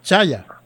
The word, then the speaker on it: chaya: Chaya, así se pronuncia, Sonia.